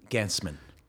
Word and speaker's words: Gancman: Gancman.